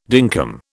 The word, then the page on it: dinkum: British English